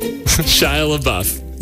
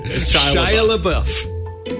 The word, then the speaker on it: Shia LaBeouf: Shia LaBeouf? – Shia LaBeouf. – LaBeouf. – You got it. – The spelling is awful. – Pronunciation. – Shia LaBeouf.